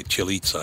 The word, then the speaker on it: Cillizza: At CNN, Chris Chilitza, is that how you say his name? C-I-L-L-I-C-Z-A. That would be Chilitza in Italian. – Yeah. I think so. – C-I-L-L-I-Z-Z-A, Chilitza, I think.